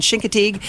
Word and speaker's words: Chincoteague: It's pronounced Assateague and Chincoteague.